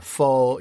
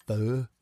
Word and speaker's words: pho: I would never go, actually, it's pronounced pho. – Okay, everyone. Pho show. Like, they're making the restaurants rhyme pho real.